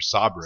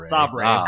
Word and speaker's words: Sabre: It's Saber Interactive or Sabre. – Sabre. – Sabre. – What? – It is spelled Saber, though.